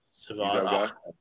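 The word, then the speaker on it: Salvon Ahmed: Salvin Ahmed.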